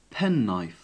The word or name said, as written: penknife